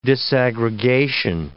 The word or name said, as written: disaggregation